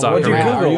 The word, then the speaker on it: ocarina: Well, I googled it, and like every single result was saying it's Ocarina.